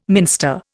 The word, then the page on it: minster: Indian